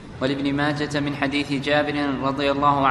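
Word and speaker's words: ماجه: نعم، هذا حديث ابن ماجة، وذكرت لكم قبل أن نبدأ ماجة المحدثين ينطقونه بالهاء الساكنة مطلقا، حال الوصل وحال الوصل، حال الوصل وحال الوقف، ولا ينطق بالتاء.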